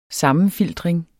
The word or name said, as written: sammenfiltring